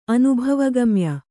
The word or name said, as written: ಅನುಭವಗಮ್ಯ